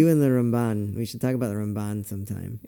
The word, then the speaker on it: Ramban: And Ramban when mm-hmm. talking about Nachmanides.